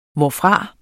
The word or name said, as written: hvorfra